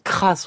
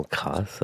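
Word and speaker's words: Cras: Autre extrait et autre musique française sur ce disque After a Dream, voici une suite en duo de Jean Kras. On prononce bien le S, la famille nous l'a dit et redit. – Thank you. – modéré, deuxième mouvement de la suite en duo de Jean Crasse avec Frédéric Chatou à la flûte, Emmanuel Cesson à la harpe, qui est notre invité ce matin.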